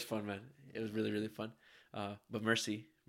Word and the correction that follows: Merci: Merci, right? – Merci beaucoup.